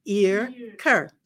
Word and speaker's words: Earker: Well, my name is not Earker. It's Earker, just like it's spelled.